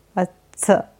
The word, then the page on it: Vatsa: Sanskrit